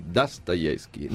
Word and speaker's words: Dostoevskij: E adesso è uscito un nuovo saggio, una nuova interpretazione di Dostoevskij. Notate la pronuncia, perché la prima vocale diventa A.